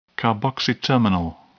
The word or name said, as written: carboxy-terminal